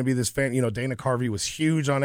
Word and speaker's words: Dana Carvey: Did I say that wrong? – You said Danny Carvey. – Oh, sorry. It's the cold. – Yeah, let's just blame it on the suit. – The Dana Carvey Show, which should have been this great show, came out in 1996.